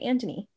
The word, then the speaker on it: Antony: oh, why don't they say Anthony? But that's how the British say it. They say Antony.